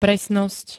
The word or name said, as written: presnosť